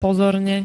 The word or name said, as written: pozorne